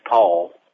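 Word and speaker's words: Paugh: Yeah, I'm Pastor Butch Paul. The last name is spelled P-A-U-G-H, P-A-U-G-H.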